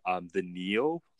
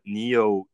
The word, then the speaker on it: NIO: It's not confirmed that they will ever be here, but the NIO line, the NIO, I think it's pronounced Neo.